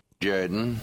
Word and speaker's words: Jordan: No. It's pronounced Jordan.